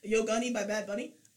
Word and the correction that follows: Yonaguni: Did I say that right? – Yonaguni.